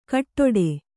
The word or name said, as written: ಕಾಡಟ್ಟು